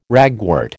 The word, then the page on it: ragwort: American English